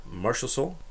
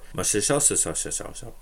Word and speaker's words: Marchessault: Thomas Hika, William Carlson, Brendan Lepsic from the Maple Leafs, Oscar Lindberg, Jonathan Marchessault, or Marchessault, as I believe I pronounced his name before.